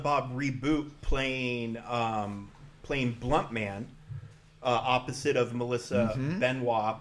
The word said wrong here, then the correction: Benoist: playing, or Benoist, I guess actually is how it's pronounced, playing Chronic.